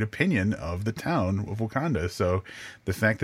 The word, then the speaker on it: Wauconda: because I I from Wauconda Illinois W A U C O N D A uh, Wauconda, Illinois, which is pronounced the same way as the Wakanda from Black Panther.